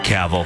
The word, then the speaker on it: Cavill: Cavill. – Yep. Cavill.